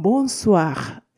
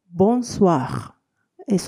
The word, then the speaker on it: Bonsoir: えー、ボンスワー、えー、この、お、お、いとの部分を、おわと発音するので、ボンスワー、えー、そして、えー、がその後つきますので、ボンスワー。